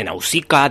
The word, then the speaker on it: Nausicaa: Hoy en nuestra sección Críticas en un minuto te vamos a hablar de Nausicaa del Valle del Viento. Lo pronuncio de esta forma porque es tal y como lo dicen los japoneses.